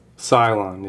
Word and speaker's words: scion: Cylon is how it's pronounced.